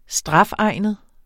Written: strafegnet

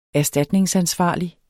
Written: erstatningsansvarlig

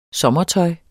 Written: sommertøj

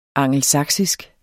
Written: angelsaksisk

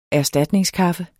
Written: erstatningskaffe

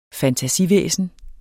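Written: FANTASIVÆSNER

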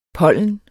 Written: pollen

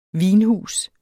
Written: vinhus

